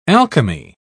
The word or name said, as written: alchemy